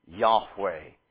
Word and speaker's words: Yahweh: And so we pronounce the name Yahweh.